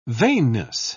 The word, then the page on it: vainness: British English